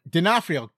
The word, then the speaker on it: D'Onofrio: D'Onofrio. God, that's how you say his name. I knew how to say his name. I have been pronouncing it on and off correctly, and sometimes I forget how to pronounce it.